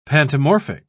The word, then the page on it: pantomorphic: British English